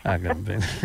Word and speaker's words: Agamben: E questo stato di eccezione, dove risuonano anche le parole di Giorgio Agamben, o Agamben, qui non riesco mai a pronunciarlo. Agamben. Grazie.